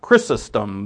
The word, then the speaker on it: Chrysostom: Chrysostom, that's how you pronounce it.